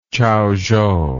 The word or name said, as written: Chaozhou